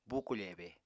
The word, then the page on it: bukofebe: Apatani